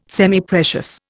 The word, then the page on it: Semiprecious: Indian